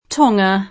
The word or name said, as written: Tonga